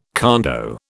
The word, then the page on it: condo: British English